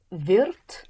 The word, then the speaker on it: wird: Er wird. – Er wird. For will is spelled W-I-R-D.